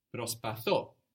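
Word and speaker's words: Prospatho (προσπαθώ): But with I try, we have the accent on the end. Prospatho. So there's a group of verbs in Greek that have their accents on the end, like this one, prospatho.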